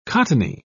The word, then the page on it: cottony: British English